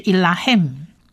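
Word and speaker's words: Elohim (אלוהים): 希伯来文字母 Man 放字尾发鼻音嗯的音，所以变成整体的整个字发音就是 Elohim， Elohim， Elohim 是他参战或他攻打，或者最简单你也可以讲说他征战，他征战也可以。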